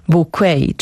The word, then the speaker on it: Bucket: Jeszcze w kontekście serialu Co ludzie powiedzą, to właśnie jestem ciekawa, czy Państwo też oglądali, czy pamiętają przygody Hiacynty Bucket, czyli Hiacynty Wiadro, tak właściwie, która bardzo by chciała, żeby jej nazwisko było wymawiane bouquet, czyli bukiet po prostu.